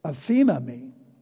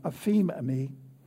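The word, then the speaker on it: aphemami (ἀφίημι): It's aphemami, A P H I E M I. That's how it's pro- pronounced, aphemami. And it means to send forth, to send away.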